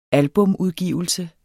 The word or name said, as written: albumudgivelse